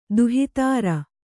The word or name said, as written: ದುಹಿತೃ